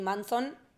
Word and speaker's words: Munson: Eh, em, Aparte también me gusta el juego del apellido de Eddie Manson, que se escribe Munson, pero suena como Manson.